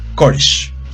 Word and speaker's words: Koresh: Eh, se cambia el nombre a David Collier, Kosher. Permítame lo mm. reviso si lo estoy pronunciando bien, porque es importante. Koresh. David Koresh.